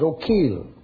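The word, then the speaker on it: Eochaill: Dun, spelled E-O-C-H-A-I-L, but it's O'Keel. Dun, Canar. How in the world do you get Dun Canar out of C-O-N-C-H-O-B-H-A-I-R?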